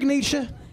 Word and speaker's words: Nietzsche: Friedrich Nietzsche, am I pronouncing that correctly? – Jawohl.